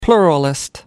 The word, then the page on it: pluralist: American English